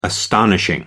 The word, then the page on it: astonishing: American English